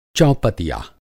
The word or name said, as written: चौपतिया